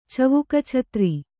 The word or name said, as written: ಚವುಕಛತ್ರಿ